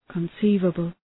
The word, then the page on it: conceivable: {kən’si:vəbəl}